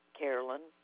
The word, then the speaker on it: Carolyn: Carolyn Williams. – Could you spell that for me, please? – C-A-R-O-L-Y-N. W-I-L-L-I-A-M-S.